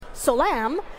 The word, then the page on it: Locem: /^solam/; Phyrexian